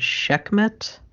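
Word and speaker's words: Sekhmet: Hathor and Shekhmet, if that's the correct pronunciation. I'm waiting for him to murder me later and be like, you pronounced that wrong. – You did it right.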